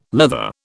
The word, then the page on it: leather: British English